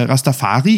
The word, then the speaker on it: Rastafari: Rastafari, ich sage jetzt einfach mal, ich äh, einige, äh, einigen wir uns auf die deutsche Aussprache.